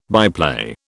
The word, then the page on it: byplay: British English